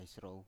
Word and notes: isrow: Biak

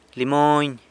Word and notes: limony: sanlucasquiavini

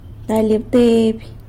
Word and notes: galy abteiby: sanlucasquiavini

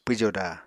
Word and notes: pwjoda: Apatani

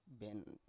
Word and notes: ben: Biak

